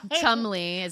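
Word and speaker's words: Cholmondeley: Chumley is how they pronounce it.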